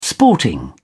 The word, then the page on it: sporting: British English